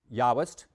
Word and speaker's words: Yahwist: The J stands for Yahwist, and it's those parts of the Hebrew Bible use the name of God as Yahweh, because this came from Germany, right? So they pronounce a J like Yah. So Yahwist.